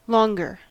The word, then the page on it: longer: American English